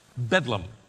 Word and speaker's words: Bedlam: St. Mary's of Bethlehem was shortened over time and simply pronounced Bedlam.